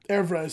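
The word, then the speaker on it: oeuvre: Evra is how it's pronounced.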